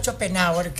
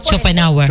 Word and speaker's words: Schopenhauer: Sí, Arturo Schopenhauer, que era... – Arturo Schopenhauer. – Schopenhauer, claro. – Ah, pues tú sabes pronunciar.